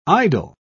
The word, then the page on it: idyl: British English